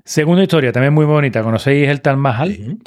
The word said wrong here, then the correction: Taj Mahal: Taj Mahal, que no lo pronuncio, He dicho Tal Mahal y tal no es, es Mahal.